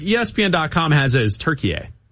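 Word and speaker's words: Türkiye: They pronounce it turkey A. – Turkey A. – Okay. Like, so over there, it's pronounced turkey A.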